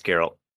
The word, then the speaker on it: Geralt: No, you're not saying it right. It's Geralt.